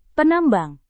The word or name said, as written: penambang